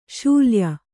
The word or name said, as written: ಶಲ್ಯೆ